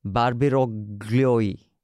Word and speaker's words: Barbiroglio: And also will be joining us is Emanuela Barbiroglio. Okay, the L is silent. I have been corrected by my producer, Lippi.